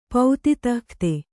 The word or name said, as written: ಪೌತಿತಃಖ್ತೆ